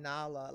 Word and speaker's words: Nala: Yo, when I say like, oh my God, the way I have my name butchered sometimes, even Nala, like, yo, they'd be like, Nila. I'm like, it's only four letters, my God.